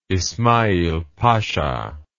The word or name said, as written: ismail pasha